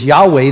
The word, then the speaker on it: Yahweh: And in fact, you can also pronounce that in the way they pronounce it in Hebrew as Yahweh, though the Israelites would never pronounce his name.